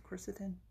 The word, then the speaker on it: quercetin: Is that how it's pronounced? Quercetin? – Yeah, quercetin, Quercetin? – that's how I pronounce it.